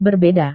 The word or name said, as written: berbeda